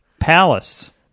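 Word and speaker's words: Pallas: Palace. Spelled like the city of Dallas, except with a P. That's P-A-L-L-A-S.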